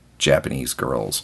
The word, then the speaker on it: JPNSGRLS: It is Japanese Girls, but it's not spelled that way. It's spelled J-P-N-S-G-R-L-S. Just take out all the vowels, Japanese Girls.